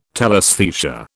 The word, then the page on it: telesthesia: British English